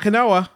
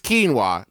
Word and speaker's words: quinoa: It's not quinoa. – Yeah, it's not pronounced that. Go ahead. – How about this? Why is it spelled that way? We might never know. But for some reason, it's quinoa, National Quinoa Month.